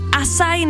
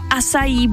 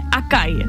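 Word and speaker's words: açaí: Asaj nebo asají bowl a ne akaj. Někdo říká akaj bowl, tak je to asaj, asaj.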